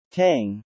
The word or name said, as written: tang